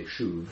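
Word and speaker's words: shuv (שׁוּב): It's English, you say shuv. I don't know what it looks like in Hebrew. It looks like that. Shuv. – And that's how you pronounce it.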